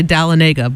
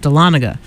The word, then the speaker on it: Dahlonega: I wanted to say Dahlonega, but it's pronounced Dahlonega.